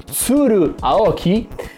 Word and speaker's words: Tsuru Aoki: Sua moglie, che adesso dico come si chiama, che non me lo ricordo mai, è Tsuru Aoki. E questa è anche la pronuncia, vedi che è una pronuncia giapponese, bravissimo.